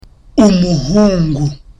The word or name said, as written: umuhungu